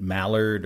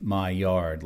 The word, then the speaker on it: Maillard: Uh, this is spelled M A I L L A R D in the past, I've pronounced it Mallard or Millard, but it is Maillard. Um, apparently, in French, it would be pronounced Maillard, but in English, it's pronounced my yard. Like, get out of my yard! My yard effect.